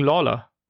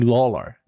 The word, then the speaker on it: Lawler: Jerry the King Lawyer. Lawler, ich kenne jetzt seinen Namen, ich krieg's nicht hin. Magst du ihn einmal richtig aussprechen? Jerry the King Lawler. Jerry the King Lawler.